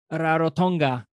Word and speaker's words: Rarotonga: She already joined us for an episode where we talk about Rarotonga. Do I still say it correctly? – Yep. – Okay. – Absolutely.